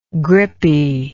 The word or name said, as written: grippy